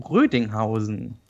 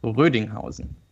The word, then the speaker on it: Rödinghausen: So it's an U. Uh, I guess it's a long U. I'm not quite sure. I don't know the place myself, obviously. but uh, but I, I, would I would think it's a long U, so it should have should be Rödinghausen.